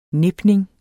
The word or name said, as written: nipning